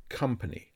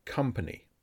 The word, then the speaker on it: company: The letter O is pronounced as the weak A sound. Company. Company.